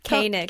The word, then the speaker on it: Koenig: Koenig. – Koenig. How do you spell it? – K-O-E-N-I-G.